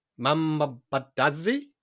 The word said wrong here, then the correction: Mandazi: Bro, it's pronounced Mandazi, otherwise known as Mahamri.